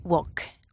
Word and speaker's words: walk: Walk. – Walk, sin la L. – Sin la L, claro.